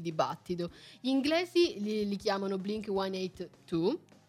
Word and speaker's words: Blink 182: in un tweet subito fu chiesto, diciamo, a lui quindi la soluzione dell'enigma, chiedendomi come lui lo pronunciasse. E la risposta è stata Blink 182.